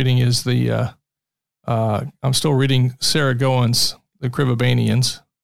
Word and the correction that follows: Crevabanians: Crevabanian. – Yeah. What'd you say? – It's more of a creve sound at the beginning.